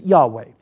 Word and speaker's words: Yahweh: And so we're not really sure, but most scholars believe that the way it should be pronounced is Yahweh.